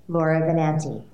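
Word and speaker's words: Laura Benanti: And here I am welcoming Lara Benanti to the podcast. Enjoy. – A-OK. – My guest today is the Tony Award-winning actress, Lara Benanti.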